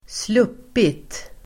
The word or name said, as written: sluppit